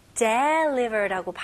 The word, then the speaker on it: deliver: Deliver라고 발음하는 경우가 거의 없습니다. 오케이? 다시 해볼게요. Deliver. Deliver. 오케이? 강세는 리에 해주시면 좋고요.